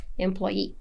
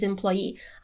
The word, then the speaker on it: employee: She is indeed our most specialized employee, for example. So she is indeed our most specialized employee. I'm intensifying our most specialized employee. Employee. Be careful with the pronunciation here. The ye is stressed, okay?